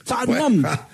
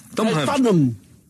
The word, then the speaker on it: Tottenham: Tottenham Hotspur. Tottenham. O sea, el ten, o como decís, Tottenham. El ten no se pronuncia. – Se lo saltan. – Tottenham.